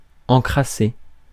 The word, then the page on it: encrassé: /ɑ̃.kʁa.se/